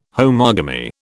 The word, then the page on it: homogamy: British English